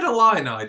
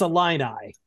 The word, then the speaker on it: Illini: I said Illini, didn't I? – Illini. I said Illini. You said Illini. It's Illini.